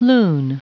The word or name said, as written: loon